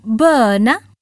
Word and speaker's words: böna: In Swedish, we say en böna, plural, bönor. So it's spelled B, E, N, A. E is the letter which looks like an O with two dots on the top.